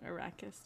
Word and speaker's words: Arrakis: Oh, I pronounced it wrong. Arrakis. Yeah, you're right.